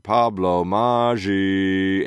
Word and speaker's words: Pablo Maggi: Big shout out to Pablo Meiji. Wait. Hold on. I'm going to say it right. Joe DiMaggio. Maggi. Pablo Maggi, a.k.a.